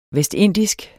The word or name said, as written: VESTINDISK